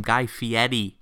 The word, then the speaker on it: Fieri: Yeah, that's how you say his name, Guy Fieri.